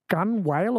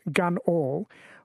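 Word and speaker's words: gunwale: For Rolly, on the pronunciation thing, very nautical, and I might need your help with this, but gun whale or gun whale? – Oh, gunnel, yes. – Gunnel pronounced gun all.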